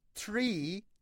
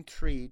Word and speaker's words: three: ببینید نباید بگین tree چون tree بگین یعنی درخت. نباید بگین three. این th تلفظ سه نداره. نباید بگین free که اصلا f هستش. ببینید یک چیزی بین F و T. Three.